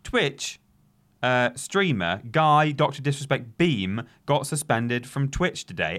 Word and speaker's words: Beahm: How do you pronounce his surname? B-E-A-H-M. Beam? Beam? Beam. – Beam. – Beam? – Beam. – We'll just say Guy Beam.